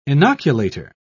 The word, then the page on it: inoculator: British English